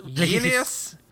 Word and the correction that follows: Glynnis: Glynnis. – There's no I in the middle.